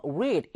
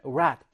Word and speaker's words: read: 우리 동사 원형은요, read 이렇게 읽어주지만 과거형 같은 경우에는 read 이런 식으로 발음이 달라지는 또 특징을 가지고 있다는 거 주의하셔야 되는 부분이에요.